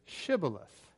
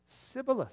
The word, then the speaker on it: Shibboleth: And if the man said no, then they said, well, then let's hear you say Shibboleth. And the men of Ephraim couldn't pronounce their SHs, and so they said Sibboleth.